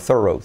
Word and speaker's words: thorough: El truco de pronunciación, acuérdate de thorough, pero como he dicho por un nativo, thorough, thorough.